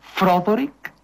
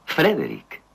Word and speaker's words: Frederick: ¿Dice usted también Froderick? No, Frederick. ¿Y por qué no es Froderick Frankenstein? Porque no, es Frederick Frankenstein.